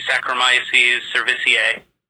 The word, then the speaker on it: Saccharomyces cerevisiae: And Ziggy is actually Saccharomyces cerevisiae. Is how you pronounce his name.